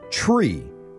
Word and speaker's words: Chree: So when Tennille got pregnant, she decided to name her daughter Tree. And spelled it C-H-R-E-E. So Tree.